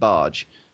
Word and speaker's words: Bargh: Because if it's spelled B-A-R-G-H, then it's pronounced Barge.